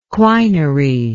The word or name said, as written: quinary